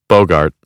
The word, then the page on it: bogart: American English; ɑːr